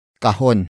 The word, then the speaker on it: kahon: And that object is kahon. Kahon. Kahon. Kahon is spelled as K-A-H-O-N.